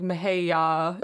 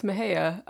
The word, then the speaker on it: Mahaya: Yeah, I have, because it sounds like, well, my name is hard to pronounce, and it isn't exactly. It's not like Mahaya. It's Mahaya.